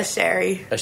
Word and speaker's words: Asherie: Asherie.